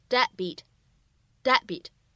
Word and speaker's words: deadbeat: 那么此时呢，两个爆破音相遇，你会发现呢，第一个爆破音它其实只做了口型，并没有特别清晰明显的读出来，所以呢，此时我们直接读成 deadbeat，deadbeat。